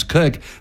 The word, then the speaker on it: Koch: Or my last name, spelled K-O-C-H, which we pronounce Cook, that's just bizarre, which is why I go by Dr. Cathy professionally.